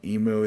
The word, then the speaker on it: emu: Emu. A little bit of mew in there.